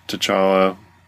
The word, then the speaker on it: T'Challa: and so, the second issue, you know, kind of starts out with T'Challa, which is how you pronounce it, apparently. I don't know why I thought, like, the T just kind of flowed into the CH sound, but we were cleared up of that misconception when we went to see Civil War.